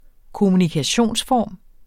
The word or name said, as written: kommunikationsform